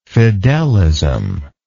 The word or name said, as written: fidelist